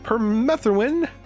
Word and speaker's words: permethrin: So we are going to talk with Pina again, and we're talking about permethrin. That's how you pronounce it. Permethrin.